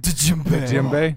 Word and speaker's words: djembe: I hate people that, like, pronounce that are heavy on the D in djembe. – Oh. – It's like, oh. – Djembe.